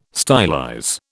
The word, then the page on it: stylize: British English